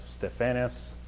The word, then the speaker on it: Stephanas: And I baptized also the household of Stephanas.